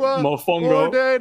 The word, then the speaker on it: Mofongo: Props to Outlaw Barber for getting Josh to say Monfongo and Mangu. – Did I say it right? Did I do it right? – The N is silent. It's like Mofongo.